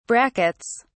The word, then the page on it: brackets: British English